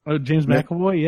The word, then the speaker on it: James McAvoy: I think James McAvoy, I think that's how you say his name, the newer... – James McAvoy, yeah.